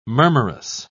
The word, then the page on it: murmurous: British English